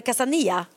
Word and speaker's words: Casa Nilla: Ja, Casa Nilla är också fint i och för sig, men då uttalas det Casa Nia, för man säger ju inte L i Spanien.